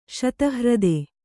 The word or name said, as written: ಶತಹ್ರದ